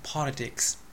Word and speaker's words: politics: ついでに名詞 politics、これはアクセント第一で S が必ずつく。